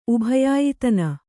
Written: ಉಭಯಾಯಿತನ

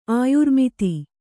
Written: ಆಯುರ್ಮಿತಿ

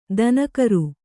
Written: ದಿನಕರ

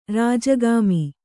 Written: ರಾಜಗಾಮಿ

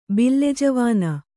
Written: ಬಿಲ್ಲೆಜವಾನ

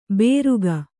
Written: ಬೇರುಗ

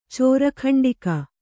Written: ಚೋರಕಂಡಿಕ

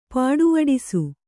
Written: ಪೊಡೆವಡಿಸು

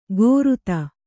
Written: ಗೋರುತ

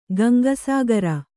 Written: ಗಂಗಾಸಾಗರ

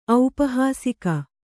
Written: ಔಪಹಾಸಿಕ

